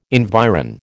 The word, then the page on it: environ: American English